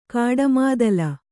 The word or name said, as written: ಕಾಡಮಾದಲ